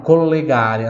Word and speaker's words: collegare: Vamos lá, collegare, a terceira palavra, collegare.